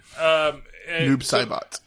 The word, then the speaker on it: Noob Saibot: Up, Sabat. – Yeah, Noob Saibot. – I know, I said it wrong, but Noob Saibot.